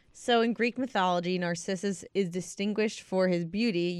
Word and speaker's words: Narcissus: Narcissus. Am I saying it right, Alan? – Narcissus.